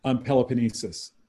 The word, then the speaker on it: Peloponnesus: Peloponnesus.